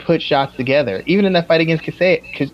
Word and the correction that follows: Chiesa: I keep saying his name wrong. He didn't really put combination. Yeah. Anthony, the one he fought this week, Michael Kaseya. Is it Kaseya? Chiesa, sorry.